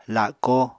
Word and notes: lako: apatani

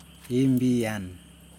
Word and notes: imbijen: Yokoim

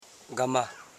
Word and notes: gama: ga.ma

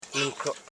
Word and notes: nunkro: nun.kro; Koro

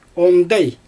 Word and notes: ondaj: Xyzyl

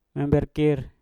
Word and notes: emberkir: Mpur

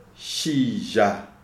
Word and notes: Shizha: Shona

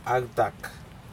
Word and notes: actak: Aneityum

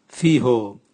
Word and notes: viho: Raga

